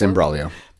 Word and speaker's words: Imbroglio: Like, it's, I appreciate that you, even like it, within Imbroglio, right? Is it? Imbroglio, the band? – Yes, Imbroglio. – I pronounced it correctly.